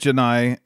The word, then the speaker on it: Jedi: Jedi. It's like the Jedi. It's like Jedi with an N.